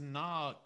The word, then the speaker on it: Kna: I actually think it might be pronounced Na, K-N-A, with a silent K. It could just be like, nah, like me.